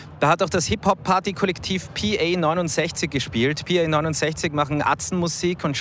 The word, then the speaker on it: PA69: Wir haben mit PA69 geredet über hässliche deutsche Wörter schön machen und ob ich ihren Bandnamen eh richtig ausspreche. – Ich sag PA69. – Correct.